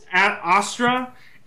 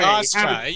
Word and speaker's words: Austra: Astora Habitat? How do you say that? – wow, yeah, that's hard. You got me on that one. – Astora, Astra, A-U-S-T-R-A. – Astra.